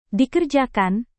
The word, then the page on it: dikerjakan: indonesian